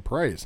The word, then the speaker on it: Proyas: Proyas? Yeah, I couldn't say his name. Proyas.